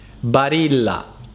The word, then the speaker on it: Barilla: quindi ricordatevi che la pronuncia corretta è Barilla.